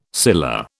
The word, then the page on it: scilla: British English